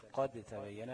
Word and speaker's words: قد تبين: مثال قد تبين، ديت مش هتنطق الدال، تنطق ايه؟ تاء واحدة، قد تبين، ماشي؟ مفيش دال بقى، مش قد تبين، لا قد تبين، ماشي؟